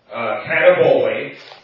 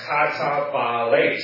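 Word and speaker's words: katabolēs: And we pronounce it, it's not katabole, it's katabales.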